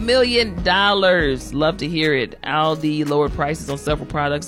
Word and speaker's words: Aldi: Number three, shout out to Aldi. I call it Aldi. Some of you might call it Aldi. It's spelled A-L-D-I.